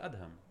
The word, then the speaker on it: Edhem: They pronounce it Adham. – Adham. – So Ed. – Oh, really? – Like E-D and then Adham. So it's not A-D, it's E-D. – No, E-D. – So E-D-H-E-M.